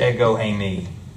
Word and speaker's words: ego eimi: So in the Greek, the actual Greek expression here is pronounced ego eimi.